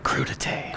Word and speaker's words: crudité: Where when you hear somebody, how do you pronounce it? Crudité. – Crudité.